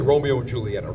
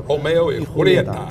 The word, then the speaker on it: Romeo y Julieta: Can't say Romeo and Julieta. Romeo y Julieta.